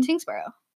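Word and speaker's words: Tyngsborough: Tingsboro. – That one's tough. I wouldn't get that one, I don't think. – Pronounce the Y like an I.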